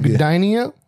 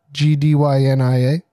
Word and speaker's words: Gdynia: There's not enough vowels. Gideon. – There's never enough vowels in Polish words. – This is why on Rum Runnas I read. – He said Gdynia. – You want to spell it? – Gdynia. – Gdynia. – Yep. Gdynia. – Dania. – Gina. – The G is silent.